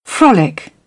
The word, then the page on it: frolic: British English; ɒ